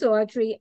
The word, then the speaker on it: Audrye: So my name is Audrye. I spell it A-U-D-R-Y-E.